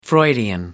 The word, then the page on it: freudian: American English